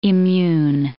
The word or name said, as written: immune